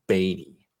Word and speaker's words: Baty: It should be Batty, B-A-T-Y, but I am looking on BREF right here, and they put B-A-I-T hyphen E-E for the pronunciation. So Batty.